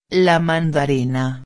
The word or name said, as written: la mandarina